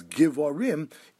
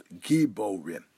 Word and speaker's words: giborim: It's based upon the drasha of a pasuk in Mishlei, where the terminology for the malachim is givorim, even though it's pronounced giborim.